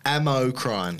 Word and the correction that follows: Amacrine: Amacrine. – Amacrine. Spell it for me. – See, I would have thought A-M-A-C-R-I-N-E.